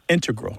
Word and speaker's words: integral: Yeah, but both correct, just to clarify here, both correct, but the mathematicians and math-using scientists are more likely to put, say, integral with the stress on the first syllable.